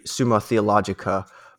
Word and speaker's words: Summa Theologica: So I guess if you were to go to Wikipedia, it's actually Summa Theologica, but it also says another way you can pronounce it is Summa Theologiae, I forget how you'd pronounce this in Latin. But anyways, it does go both ways. I think the standard way you normally say it is Summa Theologica or some variant with a C at the end.